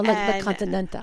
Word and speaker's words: La Continental: You have to say it real fast, though. La Continental. – And no last syllable. – No, La Continental.